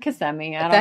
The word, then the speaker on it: Kissimmee: Also, did you know that it's pronounced Kissimmee, Florida? I always said it was Kissimmee.